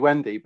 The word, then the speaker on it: Whynde: So it is officially Whynde, like the Peter Pan Whynde, right? So spelled with a bunch of crazy words. But that's fascinating that you asked, because in Norway or Sweden, they call me Vinda.